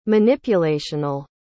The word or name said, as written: manipulational